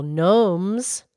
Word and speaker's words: gnome: Well, and then there's those, the little gnomes. – Oh, yeah, spell with a G, gnome, but you don't pronounce a G.